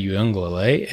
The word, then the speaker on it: Uiagalelei: Cade Klubnik, Clemson, who took over for DJ Uiagalelei. I nailed that pronunciation. – I think you did.